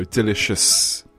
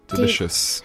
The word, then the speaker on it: delicious: So if you do want to use the word delicious, you should have heavy emphasis on the D and the sh noise. So delicious Delicious. – 好，这个跟大家说呢，如果大家想要真的用这个词，并且发音发得很好听、很标准的话，要把这个重音放在 D 和 sh.